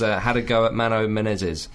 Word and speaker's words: Menezes: If only to allow to pronounce Menezes. – That's correct. – So dig, Pete. – Menezes. – Dig. – That's how you say it.